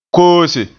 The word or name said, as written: koose